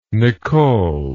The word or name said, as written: nichole